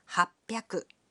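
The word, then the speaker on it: 八百: And 800 is pronounced happyaku.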